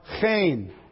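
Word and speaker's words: chen (חן): The words are chen. Chen. If we were to spell it in English, C-H, long E-N. Chen.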